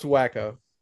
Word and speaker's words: Waco: Pronounced Waco.